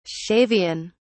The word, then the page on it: shavian: British English